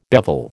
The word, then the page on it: bevel: American English